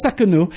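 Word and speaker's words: Tsidkenu: But Jehovah Sekenu. The T is silent. It's kind of fun. So the idea of Jehovah Sekenu is that God is our righteousness.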